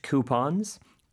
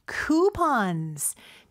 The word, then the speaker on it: coupon: 근데 쿠폰은 발음이 사실 두 가지가 있어요. – 네. – Coupon 혹은? – Coupons. – 쿠폰이라고도 할 수 있습니다. 근데 우리나라에서는요, 쿠폰으로 많이 알고 있고 사실 우리가 수능 녹음을 할 때는 꼭 쿠폰으로만 발음을 네. – 해야 되는데 실생활에서는 쿠폰이라고 하는 경우도 있으니까 여러분 가서 당황하지 마세요. 두 가지 발음이 있습니다.